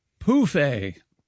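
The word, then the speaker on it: pouf: Anyway, Europeans of a certain age use the French word pronounced pouffet for an ottoman, phonetically spelled P-O-O-F hyphen A.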